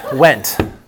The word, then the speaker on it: Wendt: Wendt. Like I went somewhere. – So W-E-N-T? W-E-N-D, as in Delta T. The D is silent.